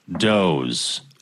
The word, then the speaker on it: Dawes: Looking forward to the Da Wez podcast." – I think that's pronounced Doze.